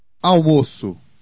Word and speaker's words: Almoço: A C with cedilla is always pronounced S. – Almoço.